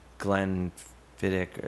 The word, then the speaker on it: Glenfiddich: But then if you were to have me drink, like, Glenfiddich, is that how you say it? – Yeah, that I find undrinkable.